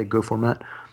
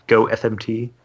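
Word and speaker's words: gofmt: But the cool thing is like with the Go Go Fumpt command, people pronounce it by like say Go format, Go FMT.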